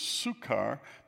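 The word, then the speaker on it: Sychar: And I said this was Sukkar because I pronounce it in German. The Y in German is Upsilon, U. And the reason I do that is sometimes the German pronunciation is closer to the Hebrew than the English.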